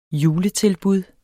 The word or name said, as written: juletilbud